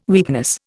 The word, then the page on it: weakness: Indian